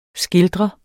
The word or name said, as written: skildrer